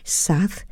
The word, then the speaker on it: Xaath: She's an artist and a singer, and she has just released an album that is called SAATH, and that is spelled X-A-A-T-H, SAATH, letter A-I.